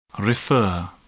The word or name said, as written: refer